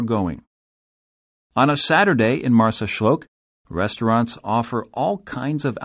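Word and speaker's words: Marsaxlokk: The picture-perfect fishing port of Marsaxloc, pronounced Marsaxloc,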